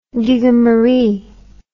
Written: gigamaree

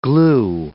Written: glue

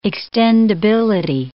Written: extendability

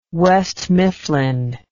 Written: west mifflin

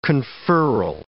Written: conferral

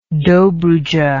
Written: dobruja

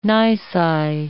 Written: nisi

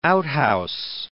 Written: outhouse

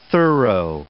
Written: thorough

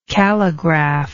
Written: calligraph

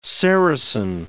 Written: sericin

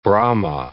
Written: Brahma

